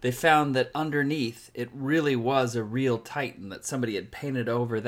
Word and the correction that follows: Titian: It's it's Titian, is it? Or oh, Titian. You're right.